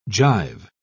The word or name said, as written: gyve